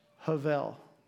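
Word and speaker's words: Havel (הבל): I'm going to spell it for you, but it's not pronounced the way that it's spelled, even though Eric is trying to mess with my mind. It's H-E-V-E-L. Is it? Maybe I put it up there. H-E-V-E-L. Havel. Havel.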